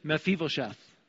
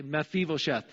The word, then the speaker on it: Mephibosheth: So we're going to break it down into four syllables here. Repeat after me. Meph, E, Vo, Sheth. Let's try it again. Meph, E, Vo, Sheth. So that name is Mephibosheth, okay? Mephibosheth.